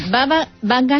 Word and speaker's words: Baba Vanga: lo que anunció una profecía llamada Baba Vanga. A ver si lo he dicho bien. – Sí, sí, Baba Vanga, así es como se la conoce familiarmente, porque ella se llama Vangelia Pandeva Dimitrova.